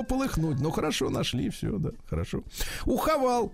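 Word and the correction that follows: Haval: то есть компания Хавейл, конечно. Пишется Хавал, но читать, читать надо как надо.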